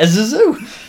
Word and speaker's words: Zazu: Yeah, Zazu. That's how we pronounce it in England.